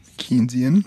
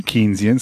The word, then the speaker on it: Keynesian: There's Austrian school and is it? – Keynesian. – Keynesian, sorry, I always say that wrong.